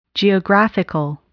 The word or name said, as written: geographical